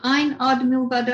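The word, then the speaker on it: Ein Od Milvado: Is it Ein Ad Milvado? Have I said that correctly? – Yes.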